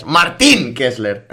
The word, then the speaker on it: Martín: Nos la manda Martín Kessler, o Martín Kessler. Martín, me ha recalcado la I. Me ha recalcado el acento en la I. Muchas gracias. Martín Kessler.